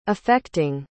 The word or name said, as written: effecting